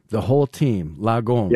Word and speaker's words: lagom: Lagom, am I saying that right? – Yeah.